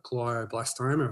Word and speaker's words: glioblastoma: And Ben was diagnosed with terminal brain cancer, glioblastoma.